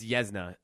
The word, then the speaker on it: Yuzna: Yeah, it's actually pronounced Yuzna. I keep fucking saying Yuzna because that's how it's spelt. It's Yuzna.